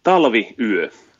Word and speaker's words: Talvi yö: Talvi yö.